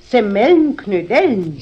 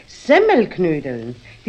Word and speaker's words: Semmelknödeln: Jetzt hast du nämlich gerade gesagt Semmelnknödeln. – Ist recht, Semmelnknödeln. – Nein, nein, richtig muss es eigentlich heißen Semmelknödeln. Die Semmel muss man dabei betonen, weil doch die Knödel aus Semmeln gemacht sind.